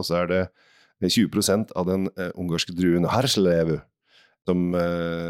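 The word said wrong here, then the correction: Hárslevelű: som du nå uttalte på dansk av en eller annen grunn, men Herslevlevu. – Herslevelevu.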